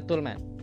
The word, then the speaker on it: Toleman: Ditulisnya Toleman ya. dibacanya Tulman ya